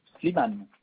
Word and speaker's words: Slimane: Slimane, comme ça se prononce avec E à la fin.